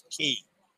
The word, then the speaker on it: Cay: Um, Aunt Dennis says, remember, you're dealing with an organization that can't properly pronounce key, C A Y, key. All the other islands in the Bahamas are key, except Perfect Day at Coco Cay.